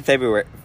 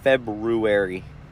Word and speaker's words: February: Yeah, only in February. February. That's how you spell it. That's not how you say it.